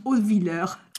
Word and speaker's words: Hautvillers: Hautvilleur. – Alors, je ne sais pas comment, peut-être que je prononce dans ma prononciation. Non, c'est vous qui avez raison.